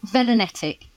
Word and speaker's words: Velonetic: Yeah, Velonetic.